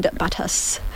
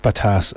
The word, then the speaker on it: Batas: We listened to, just now before coming back, Iron Veins by Shumo AG and Batas. How do you think? B-A-T-A-S. Which way would you pronounce the A in that? – Batas. – Batas. Okay, I'm going to go with that.